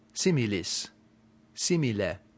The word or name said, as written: similis